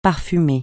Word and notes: parfumé: francais

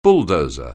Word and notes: bulldozer: anglais

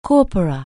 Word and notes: corpus: anglais

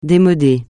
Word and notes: démodé: français

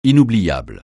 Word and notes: inoubliable: francais

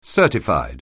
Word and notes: certified: anglais

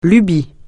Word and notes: lubie: francais

